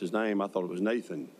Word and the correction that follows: Natan: And he got very emotional, very angry with me, screamed that that was not his name. His name was Natan.